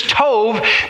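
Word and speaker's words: tov (טוב): T-O, like we would say T-O-B, so it looks like tob. But it's pronounced tov.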